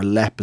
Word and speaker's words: Leap: Um, the area was originally called Léim Uí Bhanáin, and from that Lame we get Leap, or Lep as it's pronounced in this one.